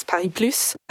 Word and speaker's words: Paris+: So I think Paris Plus, it is important that we pronounce the S, Paris Plus.